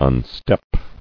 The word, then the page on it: Unstep: un·step